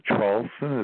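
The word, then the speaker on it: Traulsen: My name is Hugh, H-U-G-H. The last name, Charlson, is spelled T like Tom, R-A-U-L-S like Sam, E like Edward, N like Nancy.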